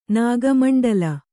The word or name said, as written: ನಾಗಮಂಡಲ